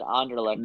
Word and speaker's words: Anderlecht: Anderlecht is how Anderlecht. That one's pronounced. – I said it right this time. – Yes, you did.